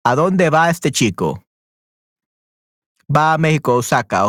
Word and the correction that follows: Oaxaca: Ok, perfecto. Muy bien. O Oaxaca. Oaxaca, I think it's pronounced.